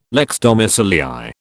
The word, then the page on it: lex domicilii: British English